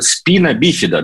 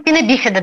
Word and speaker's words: спина бифида: Вот я про ваш фонд слышал много раз, но вот сейчас узнал, что, оказывается, спина бифида, да, правильно говорить, вот с таким ударением. – Да, мы называем спина бифида, да.